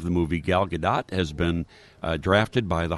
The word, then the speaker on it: Gal Gadot: By the way, Gal Gadot. That's the pronunciation.